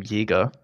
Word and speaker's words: Jaeger: But you did a perfect job on pronouncing my last name, Jaeger.